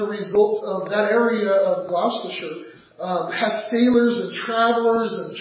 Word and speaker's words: Gloucestershire: That's how you pronounce that word, Gloucestershire. I don't know how it ends up spelled the way it is, but it's Gloucestershire.